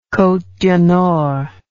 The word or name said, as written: Cotes du Nord